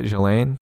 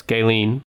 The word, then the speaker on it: Ghislaine: Is that how you say it? Ghislaine? – No, it's Ghislaine.